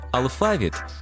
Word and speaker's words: алфавіт: Наголос у слові «алфавіт» на другому складі – основний у нашій мові, бо збігається із грецьким «алфавітон», від якого походить українська лексема.